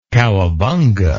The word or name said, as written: cowabunga